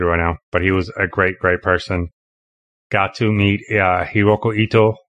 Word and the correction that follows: Hiroki Ito: Did I say that right, Kurt? Auggie? – Hiroki Ito. – Hiroki Ito, right?